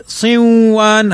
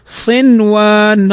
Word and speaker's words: صنوان: صنوان، صن نون ساكنة بعدها الواو، والواو من حروف الإدغام، ولكن لا نقول صنوان، هذا خطأ، وإنما نقول صنوان، ننطقهما كما هما.